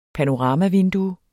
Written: panoramavindue

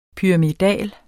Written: pyramidal

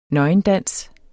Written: nøgendans

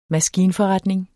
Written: maskinforretning

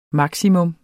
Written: maksimum

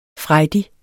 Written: frejdig

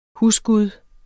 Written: HUSGUDEN

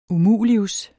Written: umulius

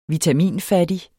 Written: vitaminfattig

